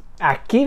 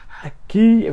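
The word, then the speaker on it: Aki (秋): It's Aki. Very good. Aki.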